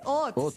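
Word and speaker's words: haute: Es que es H-A-U-T. Ah, ¿eso no es francés? Hot. Sí, eso es francés. Pues el colegio me enseñaron que era francés, sí. – Hot. Sí, hot. Pero no la pronunciación.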